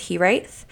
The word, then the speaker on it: hiraeth: It's a Welsh word. It's pronounced Hiraeth.